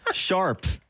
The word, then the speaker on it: sharp: 샵은 샵 영어예요. 샵. sharp 아시겠죠? 거기는 영어식으로 발음해서 그래요.